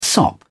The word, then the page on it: sop: British English